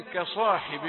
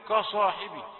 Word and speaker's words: كصاحب: ولا تقل كصاحب، معذرة، نحن نبين النطق الخطأ لنتفاداه. نسمع كثيرا كصاحبي، ك لا، كاف رقيقة تقرأها كده بلطف من غير عنف ومن غير مجهود كبير، بسلاسة بوضوح، كصا، ولا تكن كصاحب الحوت.